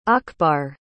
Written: akbar

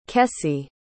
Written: kessie

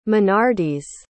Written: minardis

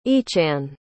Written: eachann

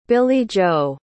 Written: billie-jo

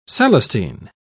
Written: celestine

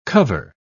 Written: cover

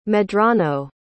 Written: medrano

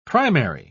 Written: primary

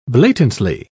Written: blatantly

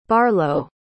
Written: barlow

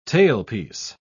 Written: tailpiece

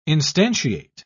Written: instantiate

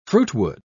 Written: fruitwood

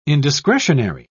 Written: indiscretionary

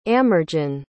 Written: amergin

